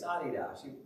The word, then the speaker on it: Adidas: How many of you know how to pronounce the word Adidas? Adidas. Isn't that something? It's Adidas. Maybe you've heard that before. The name comes from the owner. He's from Germany.